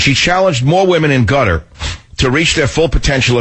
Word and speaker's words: Qatar: I still can't pronounce that country. It's Qatar, it's Qatar, it's Qatar, it's gutter.